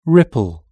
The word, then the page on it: ripple: British English